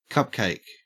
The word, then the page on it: cupcake: Australian English